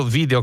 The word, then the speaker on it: video: Stessa storia, viene dal latino video che significa vedo, io vedo, pronunciatelo all'italiana.